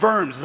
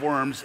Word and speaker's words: Worms: The Diet of Worms, it's not Diet of Worms. In German, you pronounce the W as a V, so Diet of Worms.